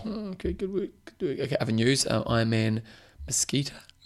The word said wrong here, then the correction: Muskoka: Mosquito, well, good pronunciation. Actually, Muskoka. – Muskoka, okay. – Muskoka.